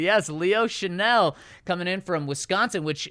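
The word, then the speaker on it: Chenal: In little words, S-H-A. – Shuh. – Dash. In all caps, N-E-L-L. – Yeah, Chanel.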